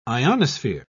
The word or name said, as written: ionosphere